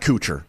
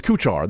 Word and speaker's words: Kuchar: It's not Kuchar. It's Kuchar. That's why the A's there. If it was an E, it'd be Kuchar.